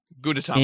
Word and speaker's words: Gudetama: Well, Sanrio has a character who's in the Hello Kitty universe named, I'm going to butcher this, I apologize, Gudetama. – Gudetama.